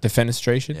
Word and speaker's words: defenestration: Defenstration? – Oh, defenestrate. – Defenestration? – Yeah, defenestration.